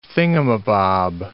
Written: thingamabob

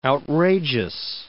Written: outrageous

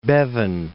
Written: Bevin

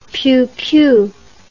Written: pioupiou

